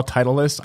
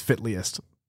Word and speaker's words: Titleist: But when I first saw Titleist, I thought it was Fitliest. That's how I pronounced it.